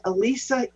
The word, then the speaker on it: Elisa: So you can use my name, Elisa, spelled with an E.